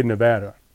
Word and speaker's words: Nevada: Nevada. How do you say it? – Nevada. – I think the locals say Nevada.